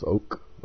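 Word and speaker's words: ouk (οὐκ): Next, a strong negative, we, ou, can be pronounced ouk, O-U-K.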